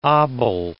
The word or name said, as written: obol